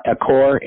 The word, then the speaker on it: Achor: Achor, A-C-H-O-R.